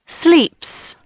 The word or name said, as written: sleeps